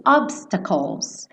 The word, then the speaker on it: obstacles: Esse obstacles. Calls, calls, tá? Esse CL, o E não vai ser pronunciado. Então, calls, bota a língua lá no céu da boca no L, tá?